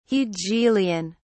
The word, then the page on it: hegelian: British English